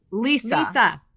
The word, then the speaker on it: Lesa: And also, but you guys, I have to say, it's Lisa. – Well, it's spelled L-E-S-A, so it's confusing. So it's not your fault. – So, but I am going to apologize. Lisa.